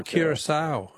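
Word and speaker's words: Curaçao: And I don't know if I'm pronouncing it right, but I thought it was Curacao. – Oh, Curacao. Yeah. No, that's very good. I'm sure you're pronouncing it correctly, but that's not...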